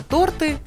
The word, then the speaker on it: торты: Значит, друзья, есть несколько слов, которые достаточно популярные в обиходе, но в которых часто неправильно ставят ударение. Это торты и банты.